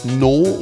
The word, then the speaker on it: Naux: Haben hier einen Track von No oder Naux, oder Naux wird es geschrieben, No wird es ausgesprochen, Need Your Love, das Ganze bei Naux Music.